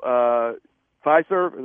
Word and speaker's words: Fiserv: And honestly, like, walking through Fiserv, am I saying it correctly? I think I am. – Yeah, you got it.